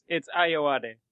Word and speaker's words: Ayoade: It's Ayoade.